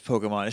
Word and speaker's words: PKMN: I think it's supposed to be pronounced as Pokemon. It's just a shorthand for writing it.